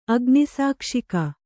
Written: ಅಗ್ನಿಸಾಕ್ಷಿಕ

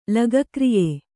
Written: ಲಗಕ್ರಿಯೆ

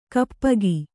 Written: ಗೋಪಿಕೆ

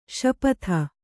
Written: ಶಪಿತ

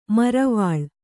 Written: ಮರವಾಳ್

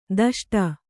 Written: ದಷ್ಟ

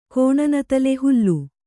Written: ಹೋಲು

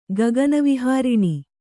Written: ಗಗನವಿಹಾರಿಣಿ

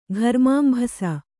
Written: ಘರ್ಮಾಂಭಸ

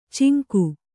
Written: ಚಂಗ

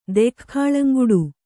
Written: ದೆಖ್ಖಾಳಂಗುಡು

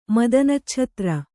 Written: ಮದನಚ್ಛತ್ರ

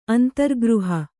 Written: ಅಂತರ್ಗೃಹ

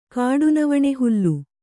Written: ಕಾಡುನವಣೆಹುಲ್ಲು

